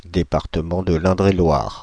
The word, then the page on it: Indre-et-Loire: France (Île-de-France)